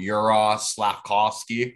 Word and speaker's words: Juraj Slavkovsky: Let me make sure I said that right again. Juraj Slavkovsky. I think I pretty much nailed that.